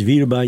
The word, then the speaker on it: Wilby: John Wilby vagy Wilby, így is úgy is hallottam már kiejteni, a szerző neve, a Hiál énekegyüttest hallották.